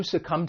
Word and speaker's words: succumb: When pronouncing this verb, it has two syllables and the stress is on that second syllable, succumb. And just keep in mind, there is a silent B at the end of the verb, to succumb.